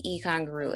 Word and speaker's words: Eigengrau: that's what Egon Gru is. – How are you spelling that? E what? – So it's spelled E-I-G-E-N-G-R-A-U. It's German for intrinsic gray. All right, Alicia. So the Egon Gru leads us into a discussion today about how to choose color.